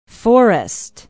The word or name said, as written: Forest